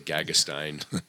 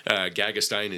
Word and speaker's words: Gagestein: Okay, Gagestein. I'll spell the first name too, A-R-L-O. – Yeah. – Gagestein is G-A-G-E-S-T-E-I-N.